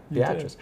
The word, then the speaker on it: Beatrice: So I pronounced it right, Beatrice. – You did.